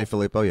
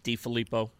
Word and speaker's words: DeFilippo: Now we're seeing how much it hurt the Eagles to lose Frank Reich and I'll say his name wrong because I always do, John DeFilippo, mm-hmm. – or Filippo. – John DeFilippo, yeah. – DeFilippo. He's the Vikings offensive coordinator.